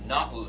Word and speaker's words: nous: Looks like nous. It's nous. It's two syllables.